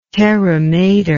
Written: terra mater

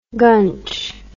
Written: gunch

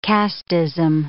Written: casteism

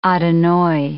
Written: Adonai

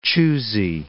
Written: choosey

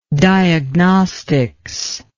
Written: diagnostics

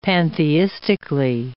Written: pantheistically

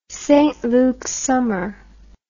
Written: St Lukes summer

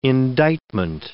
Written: indictment

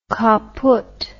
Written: caput

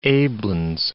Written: aiblins

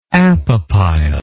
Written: apopyle

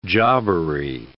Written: jobbery

